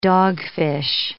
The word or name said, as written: dogfish